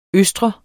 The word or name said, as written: østre